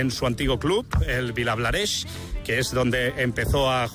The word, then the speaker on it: Vilablareix: Desde Vilablareix,